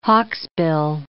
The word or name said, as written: hawksbill